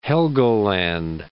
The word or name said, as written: Helgoland